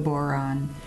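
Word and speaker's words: bodhrán: But the other instrument that I have is the boron. Rhymes with moron, but don't drum.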